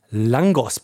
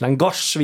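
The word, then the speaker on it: lángos: Was uns zu dem Thema Langos bringt. Langos? Das, ich sehe es hier oh, auf der Langosch, Liste. meine ich. Langosch, wie es natürlich auf Ungarisch ausgesprochen wird, glaube ich.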